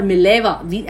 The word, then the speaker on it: Mileva: Okay, now Mileva or Mileva, it's Mileva, Mileva Maric, and it's Maric, though the spelling is M-A-R-I-C, you pronounce it as C-H, M-A-R-I-C-H, pronunciation is C-H.